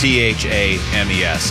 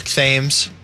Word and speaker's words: Thames: And I'm fully aware I pronounce that word like such an American. Like if I was over in London, I'd be like, hey, got that boat for the River Thames? – Yeah, that's right.